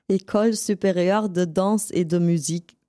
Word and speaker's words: École Supérieure de Danse et de Musique: Wie wird das richtig ausgesprochen? École Supérieure de Danse et de Musique.